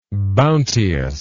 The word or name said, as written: bounteousness